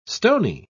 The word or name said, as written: stony